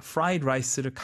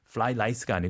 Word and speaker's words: fried rice: 여기서 발음 fried rice를 가끔 이제 좀 이렇게 혀가 꼬일 수가 있어요. F 발음, R 발음. – 어. – Fly rice가 아니고 fried rice.